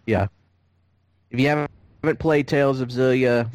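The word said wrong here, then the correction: Xillia: Tales of Zillia 2 is, first of all, it's Exilia. That's how it's pronounced.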